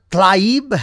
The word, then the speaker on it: Tlaib: Now that we have settled the issue that Trump isn't going to be sending AOC anywhere, or Tlaib, is how you pronounce it, or Presley, the other one, and then there's another one.